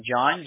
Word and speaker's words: Jon: All right, so first name, John, J-O-N. Last name is So. It's spelled S-E-O.